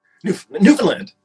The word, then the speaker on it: Newfoundland: Newfoundland.